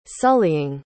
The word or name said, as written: sullying